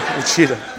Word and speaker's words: Wichita: And to me, as an outsider, yeah, how do you say Wichita? Is that how you say this? Wichita? Wichita? Yeah. Well, Wichita. There you go.